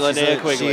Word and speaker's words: Linnea Quigley: That's right, Linnea Quigley. – Is that how you say it, Cody? – Yes, it is.